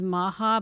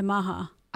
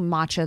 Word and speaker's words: Macha: My son-in-law's last name is Maha, but it's spelled M-A-C-H-A. – That's how you say that? – Yeah, Maha. – I've been saying matcha this whole time.